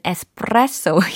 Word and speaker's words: espresso: 네, espresso, espresso 이 부분을 어 현지 이탈리아 사람들은 에스프레소 이렇게 발음을 하는 것 같더라고요.